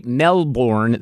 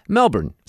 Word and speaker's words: Melbourne: So I wouldn't say Melbourne. That sounds too American. Right. I'd say Melbourne, something like that. Like, there'd still be an R in there, right? – Yeah, what?